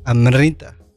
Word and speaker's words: Amrita: Antes de esta canción que está en el fondo, escuchamos a Fela Kuti y ahora estamos escuchando a Amirta Kidambi. ¿Amirta Kidambi? – Digo que se pronuncia, el apellido no me acuerdo, pero digo Amrita. – ¿Amrita? – Como yeah. La, antes de la I, antes de la R la pones antes de la I.